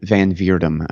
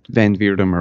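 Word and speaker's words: van Wirdum: Aaron Van Vierdum, I've been calling him Von Wertem forever, and then I had him on the show and he was like, yes, it's Van Vierdum or whatever. He's like, that's close. And I was like, oh God, I've been saying it wrong forever.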